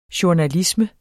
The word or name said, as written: journalisme